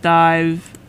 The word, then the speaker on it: DIIV: Alright, that was Out of Mind by D-I-I-V, pronounced Dive.